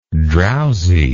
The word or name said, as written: drowsihead